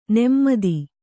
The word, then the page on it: ನೆಮ್ಮದಿ: nemmadi